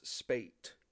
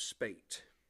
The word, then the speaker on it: Spaight: His name is Richard Dobbs Spate. It's an interesting name, Richard Dobbs Spate. His last name is spelt S-P-A-I-G-H-T.